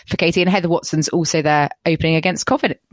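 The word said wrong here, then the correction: Kovinić: Cov- Cov- sorry, Kovinic. Yeah.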